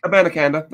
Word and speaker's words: Abanikanda: Abanikanda. That's how you say it.